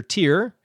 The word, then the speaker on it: Tyr: Tier, Tuesday is named after Tyr, TYR.